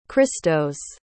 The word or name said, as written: christos